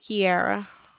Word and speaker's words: Kieara: My name is Kiera. – Honey, can you spell that? That's a beautiful name. – K-I-E-A-R-A. – A-R-A.